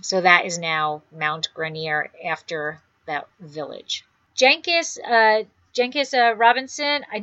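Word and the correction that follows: Jancis: Did I say it right? – Janice. – Yeah, but it's J-A-N-C-I-S.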